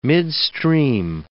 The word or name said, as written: midstream